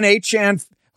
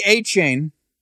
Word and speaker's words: Achane: Devon Achane, who formerly Achane, now Achane, he has decided, he's the latest NFL player that has decided to change how his name is pronounced.